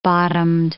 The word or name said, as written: bottomed